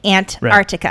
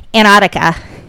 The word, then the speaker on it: Antarctica: Well, it's funny because it's spelled A-N-T-A-R-T-I-C-A, Antarctica. – Right. – And for the longest time, I would hear a lot of people, maybe it's a New England thing, but they just say Antarctica, like Antarctica. – Yeah. – And so they don't pronounce that T.